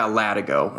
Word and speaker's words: latigo: And am I pronouncing it correctly? – Yeah, latigo, or that's at least what we call it.